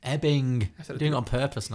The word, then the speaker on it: Ebbing: Well, you did just pronounce it Edding, Missouri, so, but until then you were doing great. It's Ebbing.